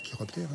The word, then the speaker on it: chiroptères: Chiroptères, hein ? – Oui, je crois que c'est ça. Vas-y, vas-y. – Les chiroptères. – L'ordre des chiroptères, ça s'écrit C-H-I. Et ça se prononce bien chiroptère.